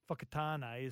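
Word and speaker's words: Whakatāne: Fakatane is spelt WH, for example.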